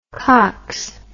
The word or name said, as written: cocks